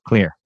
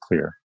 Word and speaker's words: Xlear: Spelled with an X. X-L-E-A-R. – X-L-E-A-R. – The pronunciation is from Finland because xylitol in Finnish is pronounced kulitol. So I took a Finnish X and made clear.